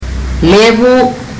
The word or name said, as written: levu